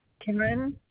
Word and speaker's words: Karen: Karen, K-A-R-E-N.